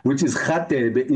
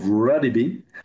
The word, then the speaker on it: Radebe: I've tried, you know, a few times just to pronounce it properly, which is Radebe instead of Radebe.